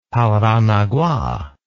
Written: paranagua

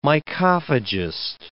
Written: mycophagist

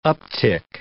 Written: uptick

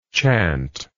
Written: chantingly

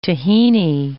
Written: tahini

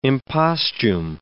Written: impostume

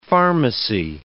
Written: pharmacy

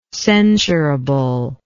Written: censurably

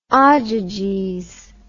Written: ogyges

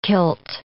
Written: kilt

